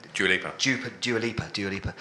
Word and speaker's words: Dua Lipa: You got to look at Deep Lipper. Is that how you say it? No, Dua Lipa.